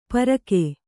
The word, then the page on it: ಪರಕೆ: parake